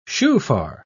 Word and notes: shofar: British English

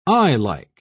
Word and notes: eyelike: British English

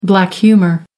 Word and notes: black humour: American English

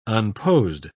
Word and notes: unposed: British English

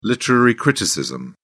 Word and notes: literary criticism: British English

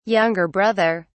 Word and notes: younger brother: English; British English

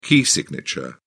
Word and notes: key signature: British English

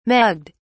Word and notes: mugged: British English

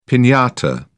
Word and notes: pinata: British English

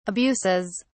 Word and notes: abuses: British English

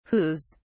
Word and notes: whos: British English

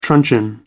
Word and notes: truncheon: American English

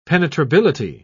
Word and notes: penetrability: British English

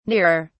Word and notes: nearer: British English

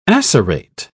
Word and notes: acerate: British English

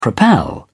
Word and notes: propel: British English

